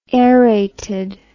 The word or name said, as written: aerated